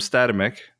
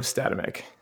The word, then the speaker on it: Statamic: The original intention was Statamic, but I think most people say Statamic because that's what it looks like.